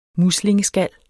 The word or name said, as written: MUSLINGESKALLER